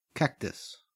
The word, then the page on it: cactus: Australian English